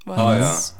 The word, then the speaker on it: Waltz: Waltz. Ah, ja. ja. Also, ist, ist ja Österreicher. Ich weiß nicht, ja. Ich weiß, ich höre nur so viele Leute die ihn auch aus Englisch aussprechen.